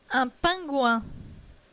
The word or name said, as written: Un pingouin